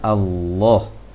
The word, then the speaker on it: Allah: Nah, karena kalimat Allah ini yang sering salah dalam praktek kaum muslimin, terbaca Allah, Allah, seharusnya Allah. Qul huwallah, huwallah. Jangan Allah, tapi huwallah, Allah, Allah. Jangan Allah. Karena Allah itu ada kebiasaan pengucapan dari bahasa Indonesia barangkali Allah, seharusnya Allah, mengarah ke O sedikit.